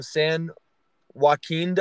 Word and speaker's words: San Joaquin: And I mean, we've had Californian guys before, but the first one from San Joaquin Delta. Did I say it right? – Yes, you did.